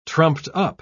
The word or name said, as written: trumped-up